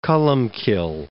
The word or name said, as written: Columcille